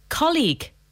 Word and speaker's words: colleague: Okay, so this word has two syllables, obviously. Colleague. – Yeah. – But a lot of people pronounce the UE on the end.